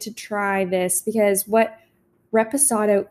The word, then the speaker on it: reposado: How did I say it? Raspado? – Raspado. – Someone's going to listen to this and be like, they have no idea. – No, that's not it. Reposado.